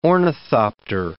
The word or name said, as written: ornithopter